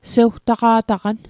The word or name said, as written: sewtaqa'taqan